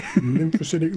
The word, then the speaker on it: lymphocytic: Did I say that right? Lymphocytic? – Yeah. Lymphocytic leukemia.